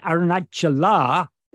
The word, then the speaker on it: Aruṇācala: And Aruṇācala, every verse of Akṣaramaṇamālai ends Aruṇācala with a long A. That is the vocative form of Aruṇācalam.